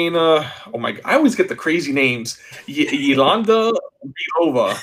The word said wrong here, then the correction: Yelena Belova: I think that's how you say it. Yelena. – Yelena, there you go. – Yelena Belova.